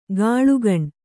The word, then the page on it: ಕಾಳ್ಕೋಣ: Kannada